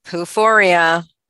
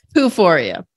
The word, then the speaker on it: poophoria: and I think you say it like this, poophoria. Is that how you say it? – Poophoria.